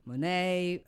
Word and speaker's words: Monet: Monet, Manet, Van Gogh.